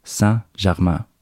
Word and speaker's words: Saint-Germain: Så for å få hjelp med dette, så snakket jeg med en kollega som er fransklærer og uttalen skal være noe i retning av Saint-Germain.